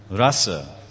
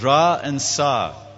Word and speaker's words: rasa: Rasa. In Sanskrit it says rasa. Rasa is two syllables, ra and sa.